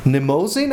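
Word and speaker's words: Mnemosyne: Nemosin, I think. – It's right. – Nemosin or something like that. It has a silent M in, at the so it's an album of Garbarek's and Hilliard Ensemble.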